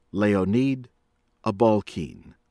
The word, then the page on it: Leonid Abalkin: American English; from Russia